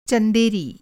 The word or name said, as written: चंदेरी